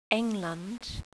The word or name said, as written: England